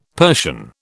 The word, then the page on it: Persian: British English